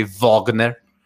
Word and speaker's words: Wagner: I do know that we have to start pronouncing his name correctly. It's actually Wagner, because, you know, that's how the Germans.